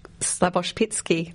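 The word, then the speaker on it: Slaboshpytskyi: Um, the Tribe is the debut film for Miroslav Slabopish. Slavoshpitsky. Oh, I knew I'd get his name wrong.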